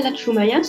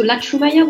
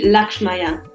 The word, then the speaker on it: Latchoumaya: So my name is Manuela Latchoumaya. So Latchoumaya would be the French pronunciation. And if you want to pronounce it maybe as it was originally pronounced, it would be Lakshmaya.